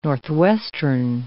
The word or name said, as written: northwestern